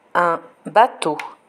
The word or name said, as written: un bateau